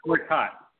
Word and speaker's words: Gortat: I think the Wizards, with Bradley Beal, John Wall, Gortach, I can never pronounce his name either. – Gortat. – I mean, Gortat. Thank you, Gortat.